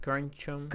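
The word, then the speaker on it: keroncong: How do you say it? Kroncong. – Kroncong. – Kroncong. – Yes, kroncong.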